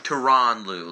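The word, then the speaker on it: Tyronn Lue: It's pronounced Tyron Lue.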